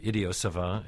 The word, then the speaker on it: idiot savant: He's also playing the title role in Richard Foreman's Idiot Savant, or Idiot Savant, if you're doing the French pronunciation.